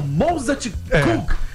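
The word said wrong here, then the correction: Mozartkugeln: Kugeln. Mozart. Que pronúncia horrorosa.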